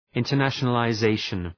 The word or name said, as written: internationalization